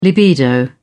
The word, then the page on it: libido: British English